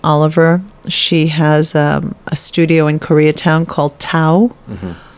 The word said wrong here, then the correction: Tao: I'm pronouncing it wrong. She would be upset with me. It's Dao. Dao. It's with a T, but you pronounce it Dao.